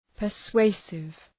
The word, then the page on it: persuasive: pər’sweısıv